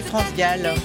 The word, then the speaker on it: France Gall: Mais tu l'as mieux prononcé. Moi j'ai dit France Sac. – Ah, tu as dit France Sac. – Ouais, France Sac, c'était une erreur bête.